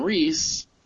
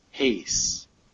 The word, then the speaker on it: Reis: The artist, yeah, it's a great show the artist that we have been referring to as Ivan Reis for many, many episodes, the actual pronunciation of his name is Ivan Hayes.